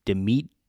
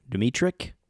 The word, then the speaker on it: Dmytryk: So Edward and then last name Dmytryk. Dmytryk from an adaptation by Emmett Lavery of the book Education for Death.